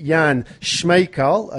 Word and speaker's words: Jan Smejkal: So to hear today to share a story with us, we have a young gentleman from Czech Republic named Jan Smejkal, if I've got the pronunciation right.